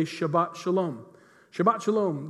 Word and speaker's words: Shabbat: In fact, on the Sabbath over there, you'll hear it all the time when you're over there, the word Sabbath in Hebrew is pronounced Shabbat.